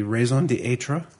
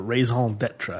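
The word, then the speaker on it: raison d'être: The raison d'etre. – Or raison d'etre, I think is the way you pronounce it. – Raison d'etre.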